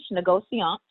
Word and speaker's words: négociant: It's French, négociant.